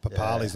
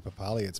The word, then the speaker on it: Papali'i: Papali's not actually Papali. It's Papali'i.